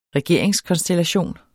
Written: regeringskonstellation

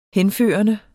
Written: henførende

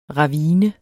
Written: ravine

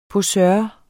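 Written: POSØREN

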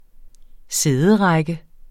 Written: sæderække

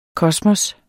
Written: kosmos

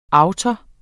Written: autor

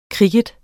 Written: kricket

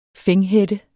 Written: fænghætte

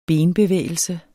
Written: benbevægelse

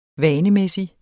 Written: vanemæssig

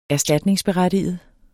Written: berettige

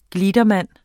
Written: gliedermann

